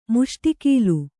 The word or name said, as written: ಮುಷ್ಟಿಕೀಲು